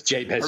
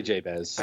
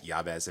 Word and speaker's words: Jabez: Can we agree on a pronunciation here? It's Jabez. – Are we going to say Jabez? – Yes, we're going to say Jabez. – Okay. I like Yabez.